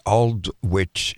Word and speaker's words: Aldwych: And it's going to be at the Aldwych Theatre. Am I pronouncing that correctly, or the British? – That's correct.